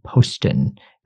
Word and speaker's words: Poston: And you focus in particular on Manzanar and Poston.